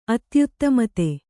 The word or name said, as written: ಅತ್ಯುತ್ತಮತೆ